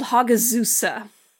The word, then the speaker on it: Hagazussa: This one is called Hagazussa. – Hagazussa.